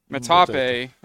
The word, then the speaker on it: Metapa: Metape, that's how you pronounce it, right? – Yeah. – So that derives from the word, I'm not going to pronounce it, it's M-E-T-L, I think it's metal, and that is derived from an agave plant called the maguey.